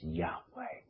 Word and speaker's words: Yahweh: No se puede pronunciar siglas. En hebreo es esas siglas, como decís, Yur, Hei, Bar, Hel. Pero la orden de nuestro Creador en Torah está escrita que demos a conocer verdaderamente el nombre. Y su nombre es Yahweh.